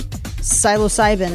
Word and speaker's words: psilocybin: Also on deck here, a compound in magic mushrooms is apparently, I can't even pronounce this, psilocybin? Sounds right.